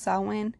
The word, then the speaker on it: Samhain: Uh, it looks like it's spelled Samhain, but it is pronounced Samhain.